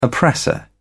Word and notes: oppressor: British English

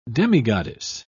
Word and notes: demigoddess: British English

